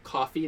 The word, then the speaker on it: CFVY: So they have like coffee that's like CFVY.